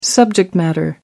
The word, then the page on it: subject matter: American English